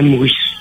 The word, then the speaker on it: Morisod: Alain Morisseau, oui. – Morisseau, ça s'écrit M-O-R-I-S-O-D.